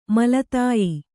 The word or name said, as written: ಮೇಲ್ದಾಯ